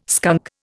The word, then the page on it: skunk: Indian